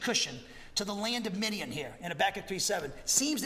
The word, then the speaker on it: Midian: However, this, because this word, Cushan, is parallel here in Habakkuk 3:7 with the proper noun Midian, yeah, it's pronounced Midian.